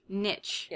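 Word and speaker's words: Knitsch: Niche. Yeah. – Scratching the Knitting Itch. It's 100% merino wool. – Yeah, it's K-N-I-T-S-C-H.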